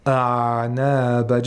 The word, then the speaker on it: أعناب: This is how ayn should be pronounced, and it requires a lot of practice, because most people, they pronounce ayn with qalqala, or they pronounce it with rakhawa, أعناب, just to make it easy. A lot of reciters do that, and it's not correct. It should have tawassut.